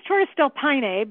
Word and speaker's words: Torres del Paine: I think it's Torres del Paine, but I think that's exactly the way you pronounced it, Paine. – Paine, that's it.